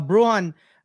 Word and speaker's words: Brujan: Yeah, Bruon had a lot of hype.